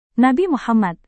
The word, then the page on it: nabi muhammad: Indonesian